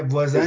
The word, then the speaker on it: Voisin: And you got to tell me if I'm making the pronunciation right. So the Laval Voisin? – Yeah, Voisin. – Is that how you say it? – Yeah, neighbors.